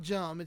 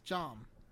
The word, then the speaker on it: jambalaya: It's not jum. It's jam. There's an A there, not a U.